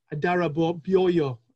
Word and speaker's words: Adarabioyo: Yep, I think that Tosin Adarabayo. Adarabioyo. I think that's how to say it.